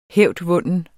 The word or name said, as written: hævdvunden